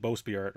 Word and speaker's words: bowsprit: I think that's how you say it, bowsprit, right? – Mm-hmm.